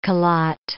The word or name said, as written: calotte